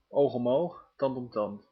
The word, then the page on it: oog om oog, tand om tand: /ˌoːx ɔm ˌoːx ˌtɑnt ɔm ˈtɑnt/